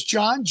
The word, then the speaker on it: Jon: My email is John, J-O-N, spelled like John Stewart from Comedy Central. My middle name, Alan, A-L-A-N, without two L's.